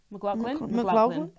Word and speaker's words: McLaughlin: Yeah, and that's so like, someone commented on one of our videos saying that Caleb McLaughlin, how do you say it? – McLaughlin? Yeah.